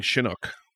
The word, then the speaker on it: Chinook: I think Chinook. Chinook. Like the helicopter.